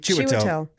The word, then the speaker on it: Chiwetel: Chiwetel? – That's it, Chiwetel.